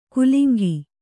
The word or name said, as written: ಕುಲಿಂಗಿ